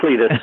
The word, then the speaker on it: Clete: Well, it's Clete, but I go by Clete. – Okay. All right. – If I go by Clete, some people think I'm a female.